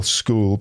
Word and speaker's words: school: Yeah, it's an interesting word as well, school, because there's not many words that have CH that are pronounced K.